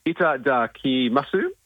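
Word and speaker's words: Itadakimasu: Ita da ki masu in Darlinghurst. – Yeah. Nailed it.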